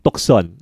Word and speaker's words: Tucson: The brand was Tucson. T-U-C-S-O-N. Tucson. It's pronounced Tucson.